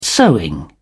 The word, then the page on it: sewing: British English